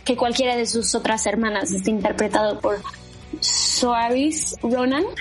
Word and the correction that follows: Saoirse Ronan: Saoirse Ronan.